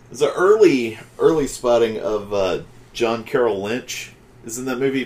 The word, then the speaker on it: John Carroll Lynch: I think it's John Carroll Lynch.